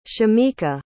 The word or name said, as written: shamika